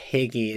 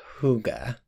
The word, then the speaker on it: Hygge: It's often mispronounced Higgy. It's actually pronounced more like Hygge.